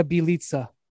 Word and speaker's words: Bjelica: Nemanja Balisha did I did I say it right? – Nemanja Bjelica, but that's okay.